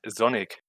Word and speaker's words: Sonic: Sonic Cartridge? – Äh, du sprichst das, glaube ich, falsch aus. Der Charakter heißt Sonic. – Ah, Sonic. Sonic. Also so, so wie Sonne spricht man das ein bisschen.